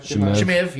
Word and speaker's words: Chimaev: I got Chimaev, or whatever, however you pronounce his name. Kimaev. – Chimaev, yep.